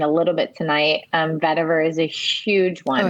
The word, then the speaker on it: vetiver: Vetiver. V E T mm-hmm. I V E R is um, is how you spell it. Okay. Vetiver.